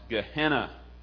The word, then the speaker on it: Gehenna: The word Jesus used for hell, as I said before, is a word in the Aramaic pronounced Gehenna.